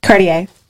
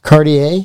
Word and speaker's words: Cartier: How do you pronounce it? – Cartier. – Cartier.